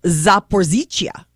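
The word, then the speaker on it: Zaporizhzhia: I did, as you know, and this is again pronounced as what? – It is Zaporizhia.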